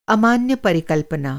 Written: अमान्य

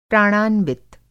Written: प्राणान्वित

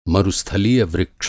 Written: मरुस्थलीय

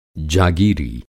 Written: जागीरी

